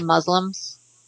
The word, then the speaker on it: Muslim: I think most people say Muslims. Muslims, that's good. – Well, yeah. I would like to pronounce it Muslim. It's a soft S with a long U.